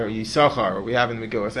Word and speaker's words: Yisachar: For example, in Parashas Vayetze, the Pasuk has one of the 12 Shvatim whose name is Yisachar. That's how we pronounce it. That's Ben Asher. Ben Naftali pronounced it Yisachar.